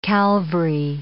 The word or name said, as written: Calvary